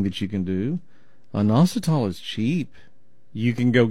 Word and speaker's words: inositol: It's called inositol. Let me spell it for you. I-N-O-S-I-T-O-L. Inositol.